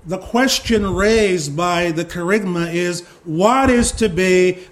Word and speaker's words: kerygma: And it's the word kerygma. Kerygma. It's spelled K-E-R-U-G-M-A.